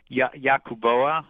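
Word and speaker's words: Yabucoa: I mispronounce it regularly. It's Yacuboa, mm-hmm. Yocuboa.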